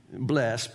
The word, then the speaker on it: blessed: Blessed, but in church, you got to add the second syllable, blessed.